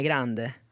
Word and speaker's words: Grande: Jag skulle nog säga Ariana Grande. Grande.